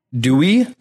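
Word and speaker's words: Duy: How do you pronounce his name? – Dewey. That's what I have. I spelled it out. Yeah. It's spelled D-U-Y. I just oftentimes heard it as Dewey.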